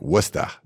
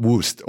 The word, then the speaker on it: Worcester: It's funny, one of the towns, it's pronounced, or it's spelled like Worcester, but it's like Worcester. – Worcester. – What, not Woost, Worcester.